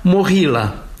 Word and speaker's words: Moghilă: Îi spunem Movilă, din familia Movileștilor, domni ai țării românești și Moldovei, însă Ucraina l-a cunoscut și îl cunoaște ca Moghilă, pronunțat Mohila.